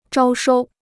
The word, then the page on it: 招收: zhāo shōu